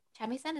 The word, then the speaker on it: shamisen: He's like, you can't play the shamisen.